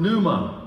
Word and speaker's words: pneuma: In the New Testament, the word in the Greek for spirit or ghost is the word pneuma. Write that one down. It's spelled P, the P is silent there, P-N-E-U-M-A, P-N-E-U-M-A.